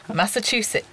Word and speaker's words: Massachusetts: How do you say that? – Massachusetts. – That's, I imagine, how you actually say it, yes.